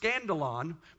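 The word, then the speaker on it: skandalon: This word offense in the New Testament Greek is actually a word that's called, it's pronounced scandalon. The word scandal comes from that.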